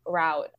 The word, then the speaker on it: route: And I was I'm doing a completely different startup route. Oh, I just said that in American pronunciation. We say root in the UK.